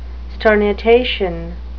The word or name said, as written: sternutation